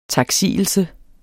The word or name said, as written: taksigelse